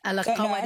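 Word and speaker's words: Al-Qarawiyyin: It's a very difficult word to say if you Al-Karawin. And is that emphasis on the Y? – Yeah.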